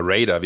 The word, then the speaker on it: Raider: Aber ich frage mich vor allem, welcher Marketing-Keck kommt denn auf die Idee, dass Ryder oder Raider, wie man es eigentlich aussprechen müsste, der in Deutschland bequemere Markenname ist als Twix.